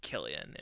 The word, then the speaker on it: Cillian: So I watched the same interview you talked about watching, and he says Killian in it. And so that's what I'm going with.